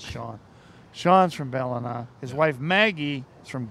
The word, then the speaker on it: Ballina: Your wife Sarah is from Ballina. Is that how you say it? – Ballina, yeah.